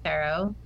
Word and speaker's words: Farough: I'm at Amanda Farough. My last name is spelled F A R O U G H.